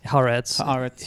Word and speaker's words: Haaretz: And so he's in Haaretz.